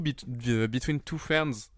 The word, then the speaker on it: Between Two Ferns: Du coup, Between Two Ferns, j'ai très mal le prononcé.